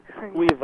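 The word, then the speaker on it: Caoimhe: That's Kaimhe? How do you say her? – Cuiva, yeah. – Cuiva. – It's written in Gaelic, actually.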